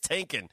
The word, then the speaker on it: Tinkin: Yeah, just like thinking. – Just like thinking, it's Tinkin.